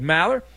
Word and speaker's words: Maller: New DraftKings customers, sign up with code Maller. How do you spell that, Ben? I'll tell you. M-A-L-L-E-R.